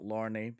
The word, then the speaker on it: Lorne Balfe: Which is why I know it's pronounced Lorne and not Lorne, because, you know, sausage. – Like the sausage. Like the sausage. Exactly. – that's how I deduced that. – Is a guy, isn't it? That's how he pronounces himself. It's Lorne, like the sausage.